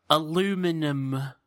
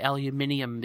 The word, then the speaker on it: aluminium: Sorry, aluminum. – Oh, okay. – Yeah, over here we say aluminium because we spell it differently even. It's the same It's the same thing, but we say aluminium foil.